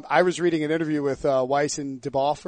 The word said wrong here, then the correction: Benioff: or whatever. Deboff? – Benioff? – Yeah, Benioff, whatever.